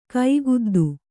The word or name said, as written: ಕೈಗುದ್ದು